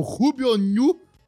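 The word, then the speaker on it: Rubio Ñu: Assinou em janeiro, rescindiu em maio e no mesmo ano iria para o Rubio New.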